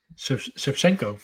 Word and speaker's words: Shevchenko: You know, funny enough, me and at the game, the announcer was calling it how do you call it? – Shevchenko. – For the Shevchenko, the proper way to say it.